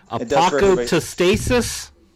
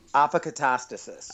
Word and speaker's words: Apokatastasis: Apocatastasis. – Apocatastasis.